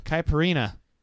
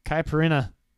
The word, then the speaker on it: Caipirinha: Which country spawned the Caperna. Ooh, I don't know how to pronounce that. – What? – Caipirinha. It's a drink. – Okay. – Caipirinha. – The Campari? – No, there's no M in this.